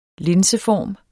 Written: linseform

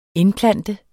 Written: INDPLANTET

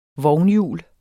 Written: vognhjul

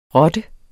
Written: rotte

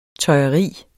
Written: tøjeri

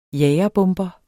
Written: jagerbomber

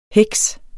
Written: heks

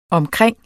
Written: omkring

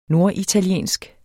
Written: norditaliensk

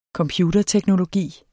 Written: computerteknologi

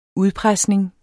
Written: udpresning

